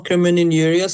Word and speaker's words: crimen injuria: I hope it does, but the listener also sent in something else that I'm actually also interested in, was crimen injuria. I hope I'm pronouncing that correctly. – You are, crimen injuria.